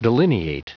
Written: delineate

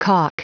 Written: caulk

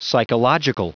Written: psychological

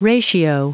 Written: ratio

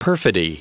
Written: perfidy

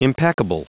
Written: impeccable

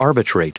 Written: arbitrate

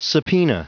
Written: subpoena

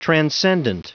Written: transcendent

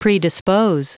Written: predispose

